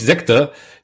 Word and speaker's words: Sekta: Der betreibt nämlich einen der besten Podcasts in Deutschland, sowohl inhaltlich als auch von der Machart her, nämlich Sekta, den Podcast über religiöse äh, Sondergemeinschaften. Das habe ich doch richtig gesagt, Fabian, oder? Ja, das hast du richtig gesagt.